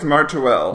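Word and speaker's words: Merteuil: How do you pronounce Glenn Close's last name? Is it? – I think it's Martuel. – Martuel, pretty sure.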